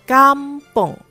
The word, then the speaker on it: kampung: 正确的是 kampung 乡村或者是村庄。 – Oops，刚才 Cikgu Lexi 不小心把 kampung 念成了 kampong。 kampong 乡下，真正的 a 加安是 k a m b u n g，而不是 k m b o n g。